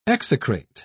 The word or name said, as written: execrate